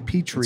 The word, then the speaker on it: Petri: Why Petro? – It's Petra. It's actually Petri. – It's Greek. – It's spelled P-E-T-R-I.